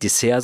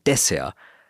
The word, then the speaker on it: Dessert: Ich habe ein paar Freunde, die auch in der Gastro arbeiten und die sagen nicht zum Beispiel Restaurant, sondern die sagen Restaurant. Die sagen auch nicht Dessert, sondern die sagen Dessert oder Patisserie. Also immer mit der Betonung auf der ersten Silbe.